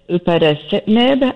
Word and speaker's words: upadacitinib: And so in studies, this medication, which I'm not very good at saying, but Rinvoq is the brand name, and it's upadacitinib, I believe is how you say the generic, has been shown to be used specifically for folks who have hair loss, as I mentioned, in those patchy spaces.